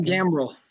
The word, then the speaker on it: gambrel: So all of your hunting accessories from, is it called a gimbal? Am I saying that right? I feel like I always say that incorrectly. – A gambrel.